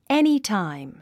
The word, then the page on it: anytime: énitàim; エニィタァイム